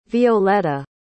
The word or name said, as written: violetta